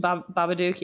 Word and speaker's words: Babadook: But then I remembered, like, that's kind of like a how do you say it? Babadook? – Babadook, yeah.